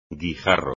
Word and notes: guijarro: espagnol

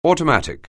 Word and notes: automatic: anglais

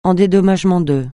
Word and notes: en dédommagement de: francais

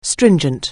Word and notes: stringent: anglais

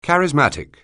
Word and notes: charismatic: anglais

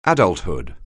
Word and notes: adulthood: anglais

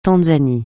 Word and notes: Tanzanie: francais

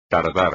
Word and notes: tardar: espagnol

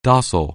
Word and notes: doable: anglais

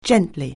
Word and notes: gently: anglais

